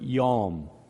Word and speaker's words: yom: And in his sovereign naming, he names the light or the illumination day, which is the Hebrew word yom, Y-O-M, would look like how it's spelled if it was on a piece of paper.